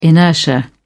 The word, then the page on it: inertia: British English